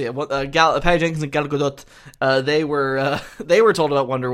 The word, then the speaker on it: Gadot: It's Gadot.